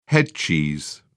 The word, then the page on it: headcheese: British English